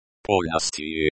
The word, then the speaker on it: Полностью: Полностью, the stress falls on the second syllable, P-O-L. The O sound is like the O in hot. The L is a soft L, as in lion. The eerie is a soft sign that doesn't have a sound on its own, but changes the sound of the preceding consonant, and the U is pronounced as U with a soft Y, as in yes.